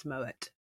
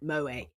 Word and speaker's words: Moët: And it's like, you pronounce it like this. It's Moët. It's not Moët. It's Moët. Yeah.